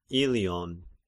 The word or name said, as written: Illion